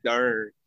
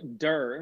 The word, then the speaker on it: Dern: One guy got 12,000 votes named Doug Dern.